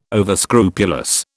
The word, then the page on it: overscrupulous: British English